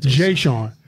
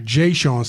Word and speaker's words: Jeshawn: We got Mr. Jeshawn Stevenson. Am I pronouncing your name correctly? – Jeshawn. – All right. Jeshawn Stevenson.